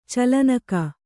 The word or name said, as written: ಚಲನೆಗೈ